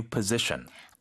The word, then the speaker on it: position: I'm thinking about applying for that new position. – I am thinking about applying for that new position. 그렇습니다. 그러니까 포지션, 직책이라는 말, position이라고 하는군요. – Position. – Position. – Z 발음이죠.